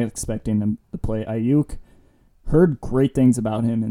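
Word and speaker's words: Aiyuk: I don't know how to pronounce the rookie Niners. Ayuk. – Brandon Ayuk. Yeah.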